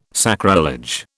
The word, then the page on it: sacrilege: British English